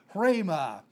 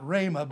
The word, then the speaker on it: hrema: We got to that in the last hour, and the word here is hrema. We spell it H-R-E-M-A, and so it's kind of a rough breathing over the letter rho in the Greek, and so we would give a rough breathing. We say not rhema, but hrema, kind of a rough breathing there.